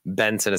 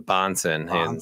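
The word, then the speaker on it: Bahnsen: See White, James White, when I said Benson instead of Bonson.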